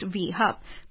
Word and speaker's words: WE Hub: and Sita Pallachola as the CEO of WE Hub, pronounced V Hub.